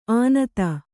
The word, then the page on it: ಆನತ: ānata